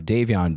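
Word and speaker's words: Devyan: And of course, though, if you just go to, you know, Davion Dumon, which if you've seen this online, you've seen how my name is spelled.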